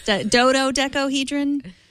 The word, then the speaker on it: dodecahedron: Dodecahedron? – Dodecahedron.